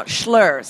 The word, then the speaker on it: Schloer: Now, Schlur, so that's spelled Schloer.